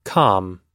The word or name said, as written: com